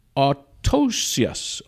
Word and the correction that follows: autoecious: Let's see. Otitius, actually.